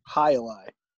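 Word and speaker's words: jai alai: How it's what's the correct pronunciation? – Hi-li. – Hi-li. – Hi-li. It's like the precursor to lacrosse.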